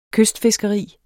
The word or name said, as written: kystfiskeri